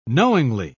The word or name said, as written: knowingly